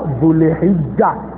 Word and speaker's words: Dhul Hijja: dhul hijja. That is the correct way of pronouncing those months.